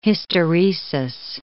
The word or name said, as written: hysteresis